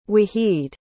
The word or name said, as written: waheed